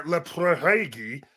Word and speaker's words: Laphroaig: well a good start is is pronouncing it Laphroaig.